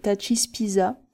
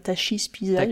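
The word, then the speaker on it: Tachyspiza: Alors, je sais pas trop comment ça se prononce, c'est T-A-C-H, donc j'ai dit Tachi, mais on peut dire Tachispiza, j'imagine. – Taki, peut-être, taki. Même, puisque c'est du grec.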